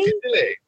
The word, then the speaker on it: fusilli: Fusilli.